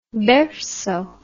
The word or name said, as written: berceau